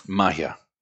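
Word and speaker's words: Magia: It's manufactured in the Dominican Republic by Mahiga Cubana. Did I say that right? – Mahia. Magic. Cuban magic is the translation. So yeah, Mahia.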